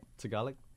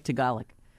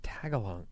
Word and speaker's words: Tagalog: I can't Tagalog. – Either. What is it? – Tagalog. – Okay, Tagalog. – Why do I think it's Tagalong? I don't know. – Well, Tagalong is close. Tagalong.